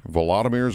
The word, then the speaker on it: Volodymyr: Vladimir Zelensky? – Volodymyr Zelensky, yeah.